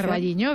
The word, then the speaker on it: Carballiño: O Carballiño, no lo sé pronunciar. – Carballiño, bien, Carballiño.